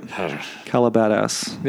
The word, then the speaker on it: Calabasas: Did I pronounce that right? Calabadas. – There you go.